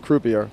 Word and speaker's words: Croupier: I think he referred to it as crappier, the British pronunciation is croupier, and it didn't play any major film festivals.